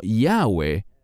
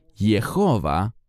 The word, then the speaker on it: Jehová: Y por eso debe ser pronunciado de esta manera y no Yahweh, como los eruditos alemanes en tiempos antiguos enseñaban. Es mucho más correcto decir Jehová o Jehová.